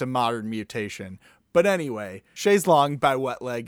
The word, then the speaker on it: Chaise Longue: And I heard this song that I add to the playlist, Chaise Longue, which thank you, Wet Leg, for teaching me the proper pronunciation, because I've been saying Chaise Lounge for years.